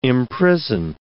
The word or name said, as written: imprison